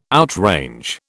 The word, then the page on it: outrange: British English